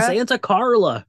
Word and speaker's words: Santa Carla: Luckily for him, we live in Santa Clara, Santa Carla. – Gosh, I will say it right at some point. Santa Carla. No.